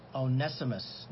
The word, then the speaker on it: Onesimus: And there's another individual who's going to be mentioned here within the passage, Onesimus.